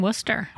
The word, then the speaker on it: Worcester: Nice job pronouncing Worcester.